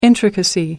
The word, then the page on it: intricacy: American English